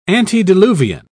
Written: antediluvian

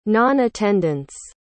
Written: non-attendance

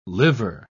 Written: liver

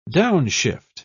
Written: downshift

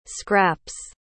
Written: scraps

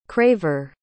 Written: craver

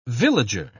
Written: villager

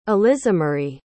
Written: elisamarie

